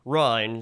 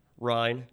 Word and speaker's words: Ryan: Now, that's how you pronounce Ryan. – Ryan. – Ryan. – Like rhinestone. – Yes. – Okay. – They pronounced it Rain.